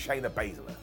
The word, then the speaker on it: Shayna Baszler: Shotzi versus Shayna Baszler.